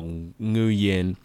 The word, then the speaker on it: Nguyen: So most people have seen the common Vietnamese name spelt N-G-U-Y-E-N. And they'll often pronounce it like Nguyen or Nguyen. And it's actually pronounced Nguyen.